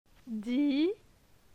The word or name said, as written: Di